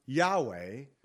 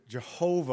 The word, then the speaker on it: Yahweh: Many scholars believe that the name of the Lord in Hebrew, written capital YHWH, the Tetragrammaton, is pronounced Yahweh. You know, others will pronounce it Jehovah or Yehovah.